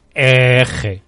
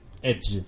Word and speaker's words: Edge: Edge, ¿cómo se dice? – Eje. – Edge. – Si eres de Madrid, Edge.